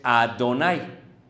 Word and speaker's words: Adonai: Ellos no dicen Jehová, ellos dicen, Adonai, que luego se translitera en español como Adonai.